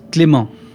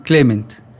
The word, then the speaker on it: Clément: Eh, en francés es Clément. – Clément. – Acá me dicen Clement.